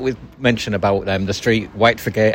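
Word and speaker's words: Whitefriargate: When you see it written down and visitors come and pronounce it correctly, they say we're looking for White Friar Gate. – That's right, yeah. But in Hull, it's White Frigate.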